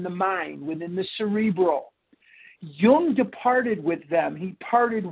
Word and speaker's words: Jung: So in 1931, Roland Hazard went to Geneva, Switzerland, to place himself under the care of Dr. Carl Jung, J-U-N-G, pronounced Jung.